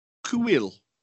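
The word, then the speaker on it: Kuiil: Yeah, and he meets a native called Kuil.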